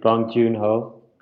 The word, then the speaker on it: Bong Joon-ho: Sorry, Shrey, but I also think just hearing you speak, Bong Joon-ho, I said it right? – Yeah.